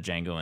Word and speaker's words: Django: He's the guy who walks up to Django and says, you know, how do you spell your name? He spells it. And he's like, the D is silent.